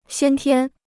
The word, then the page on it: 先天: xiān tiān